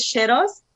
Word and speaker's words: Sherose: So the first name is Sherose.